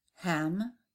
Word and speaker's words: ham: Ham for the throat chakra. Pronounce the word hum, as in humming.